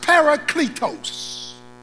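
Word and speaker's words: parakletos (παράκλητος): The Greek word for the word helper is pronounced parakletos.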